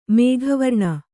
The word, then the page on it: ಮೇಘವರ್ಣ: kannada